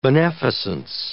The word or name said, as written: beneficence